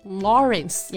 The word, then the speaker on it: Lawrence: 那我们下一个来看一看，因为饥饿游戏大火的大表姐啊，Jennifer Lawrence。 – 啊，大表姐的名发起来其实还是没有什么压力啊，直接发 Jennifer 就可以了。 – 嗯，姓呢就需要注意一下了，劳伦斯读作 Lawrence。